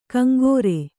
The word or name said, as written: ಕಂಗೋರೆ